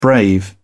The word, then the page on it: brave: British English